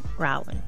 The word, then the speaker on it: Rowling: Is it Rowling or Rowling? – Rowling. – Rowling. I think so.